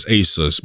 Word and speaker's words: Asus: It's Asus, by the way. So yeah, that's the correct way to pronounce it.